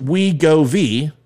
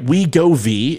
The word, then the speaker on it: Wegovy: Wegovy, pronounced Wegovy, all right, Wegovy, is a synthesized version of a gut hormone that curbs appetite.